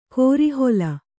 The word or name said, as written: ಕೋರೆಹಲ್ಲು